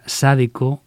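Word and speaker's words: zydeco: Pues muy bien, pues otro día te explicaré la diferencia entre cayún, que se pronuncia cayán, y cideco, que se pronuncia Sadeco o sadeco.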